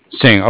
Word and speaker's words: Singh: I'm sorry, did you say your name was Singh? – Singh, yes. – Singh, okay.